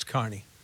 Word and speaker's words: Kearney: But for us, it's Carney, no matter what. – But it's spelled Kearney. Carney would be C-A-R-N-E-Y.